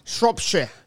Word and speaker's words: Shropshire: Shropshire. – Yeah. I can't say that correctly. – Shropshire.